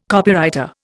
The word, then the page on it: Copywriter: Indian